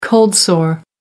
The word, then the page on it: cold sore: American English